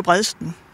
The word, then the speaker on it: Bredsten: Hvordan er det egentlig, at man udtaler? Er det Bredsten? Er det Bredsten? Er det Bredsten? Vil du ikke lige hjælpe mig med det? – Altså, jeg siger jo Bredsten.